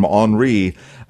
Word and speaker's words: Henri: And then on to the northeast, as they're using the French pronunciation, John, of this tropical storm, Henri.